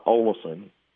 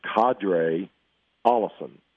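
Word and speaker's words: Qadree Ollison: Well, it's not Olison, and it's not Quadri, it's Qadre Allison.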